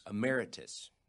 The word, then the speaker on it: Emeritus: Emeritus